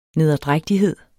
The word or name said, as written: nederdrægtighed